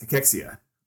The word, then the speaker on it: cachexia: We're also going to talk about what Endevica is working on in the world of cachexia. Did I say that right? – Yes, cachexia.